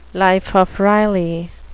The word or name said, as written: life of riley